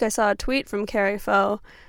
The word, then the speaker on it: Kari Faux: That's N-U-M-I. Next up, we're going to listen to Carrie Foe, who is famous. I recognize this. You probably know Carrie Foe already. It's spelled K-A-R-I-F-A-U-X. She is a pop princess.